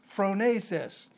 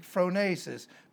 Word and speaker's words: phronesis: It is the Greek word phronesis, P-H-R-O-N-E-S-I-S in the Greek, phronesis, P-H-R-O-N-E-S-I-S, long E pronounced as an A, phronesis. It comes from the noun phrein, P-H-R-E-N, again, long E pronounced as an A, phrein, and phrein means thinking.